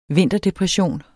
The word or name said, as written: vinterdepression